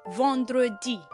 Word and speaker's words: vendredi: Friday happens to be pronounced in French language as vendredi.